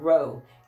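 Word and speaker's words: roeh: The first word we want to look at is the Hebrew word for um, seer, which is the word roe.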